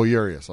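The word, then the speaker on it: Urías: Oh, Urias.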